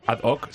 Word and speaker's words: ad hoc: Ad hoc, se dice así, ¿no? A propósito de... ¿no? – Sí, perfectamente. Muy bien dicho. – ¿Qué cultura? – Y correctamente pronunciado en el latín clásico.